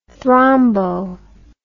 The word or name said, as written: thrombo